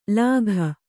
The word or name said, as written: ಲಾಘ